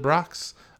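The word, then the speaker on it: Brach's: How do you pronounce it? Is it Brock's? – That's how I've always pronounced it.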